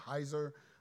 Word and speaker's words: Heiser: a guy named Michael Heiser.